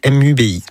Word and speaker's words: Mubi: Mubi, tout simplement.